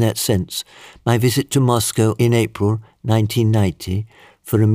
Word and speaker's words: Moscow: I said, only half-jokingly at the time, that Moscow, pronounced in English, sounds similar to must go, and so I had to go.